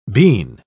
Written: bean

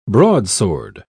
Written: broadsword

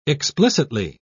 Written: explicitly

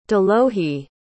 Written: delohi

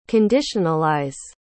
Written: conditionalize

